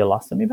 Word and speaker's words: ileostomy: the ileostomy bag? Ostomy. – Ileostomy, I'm sorry.